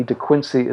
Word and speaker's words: De Quincey: And just for those who have a blind spot about the letter E, De Quincey is spelled D-E-Q-U-I-N-C-E-Y.